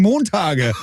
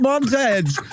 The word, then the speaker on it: Montage: Wenn man ihren Titel aussprechen würde im Deutsch, würde man sagen Montage. Aber es heißt Montage.